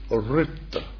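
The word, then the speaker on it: ṛta: But our task is to uphold this fundamental order of the cosmos, what is called in Sanskrit Ritta. R-I-T-A, but it's, forget the I, it's R with a dot underneath. It's the vowel sound in the Sanskrit alphabet, Ritta, you see?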